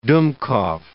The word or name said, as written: dummkopf